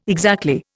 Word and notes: exactly: Indian

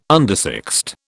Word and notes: undersexed: British English

